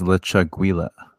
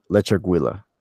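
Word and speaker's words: Lechuguilla: So this one is we are discussing what the correct pronunciation is. So I'll try, and I'll say Lechaguilla. – I think it's Lechaguilla. – Lechaguilla. Ah, that sounds better.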